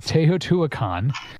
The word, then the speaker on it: Teotihuacan: Teotihuacan. Yes, never mind. I've heard this pronounced before. Teotihuacan.